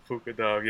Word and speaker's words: puka dog: Have a puka dog. Did I pronounce that right? – Puka dog. Yeah, man.